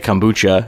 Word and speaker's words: kombucha: And when I eventually asked him what it was, he said kombucha. Am I saying that right? Kombucha? – Yeah.